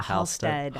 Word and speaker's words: Halstead: Mispronounced your name again, right? It's Halstead? – Halstead.